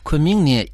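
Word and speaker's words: Кунмин (昆明): Кун произносится в первом тоне, а Мин читается во втором тоне. Повторяйте за мной. Кунмин.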